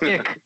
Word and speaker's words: iQ: Really, the Scion IQ, which is pronounced "ick," by the way.